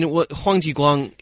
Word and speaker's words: Huang Jiguang: So I think, you know, I mean, Huang Jiguang, he was a hero.